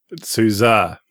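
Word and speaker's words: SUSE: And how you're supposed to say OpenSUSE. – Sousa. – Oh, okay. – There's no I in it. – Yeah. – There's no A in it either.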